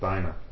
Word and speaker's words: Dinah: and Dinah, or Dina, depending on how you want to pronounce it.